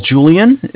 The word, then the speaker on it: Julian: All right, my name is Julian. It's J-U-L-I-A-N.